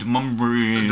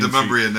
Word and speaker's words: Demonbreun: I was like, I don't care what's going on on the Brummian Street. How you say it? Demonbreun. – Demonbreun, that's right.